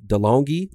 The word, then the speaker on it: DeLonghi: DeLonghi.